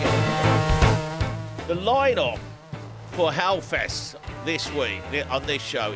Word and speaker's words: Hellfest: Now then, if you are a really, really lucky person, you are going to probably be sitting in Hellfest. And that's pronounced Elfest by the French, okay?